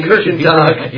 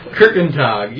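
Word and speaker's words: Kirchentag: Kirkentag. – Yeah, Kirkentag. You should be pronouncing it.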